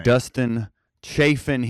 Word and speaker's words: Dustin Chafin: We have Mr. Dustin Chafin here.